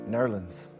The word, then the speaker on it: New Orleans: Or as it's correctly pronounced, Nerlens.